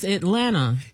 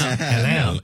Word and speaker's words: Atlanta: You have to say it right. It's Atlanta. – Yeah, you're right. You're right. And you know, I try to be so like, I try to say it the right way, but people that's from Atlanta be like, no, it's, you don't pronounce that T.